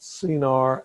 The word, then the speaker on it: Shinar: Arguably, Shinar is could be pronounced Sinar, and some scholars have argued that that was what became the first recorded imperial state of Sumer.